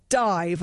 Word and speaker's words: DIIV: So I'm going to play something else from a shortly to be released album from Dive, as I recently learned how to say it, D-I-I-V. Apparently that's how you say it. The album Zerp, with an exclamation mark spelt Z-I-R-P, is out at the end of the month.